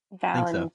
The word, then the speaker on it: Valentin: So actually, with this episode, guys, Julian Valentin, is that how you say his name? – Valentin, yes.